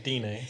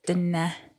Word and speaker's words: Dene: So I think I said Dene, so my bad. – I don't know how to pronounce it. – Dene. Yeah, it's Dene.